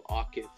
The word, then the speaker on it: Akif: I just want to correct the name pronunciation. It's Akif, so Akif.